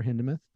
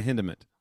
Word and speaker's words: Hindemith: I did say T-H, Hindemith. – Yeah, get your horn. – American. – The American pronunciation.